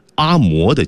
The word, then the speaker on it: 阿嬷: 再来看下一条啊，最近呢，有一部啊在朋友圈刷屏的电影《给阿嬷的情书》，注意这在当地啊应该读成阿嬷啊，但是呢正字就是容嬷嬷那嬷，各位只有一个音啊，《给阿嬷的情书》。